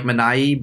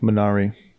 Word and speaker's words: Minari: I think Minari is fine. All right, I think it's supposed to be closer to like Minai, but no one's saying it. – Yeah, I'm going with Minari.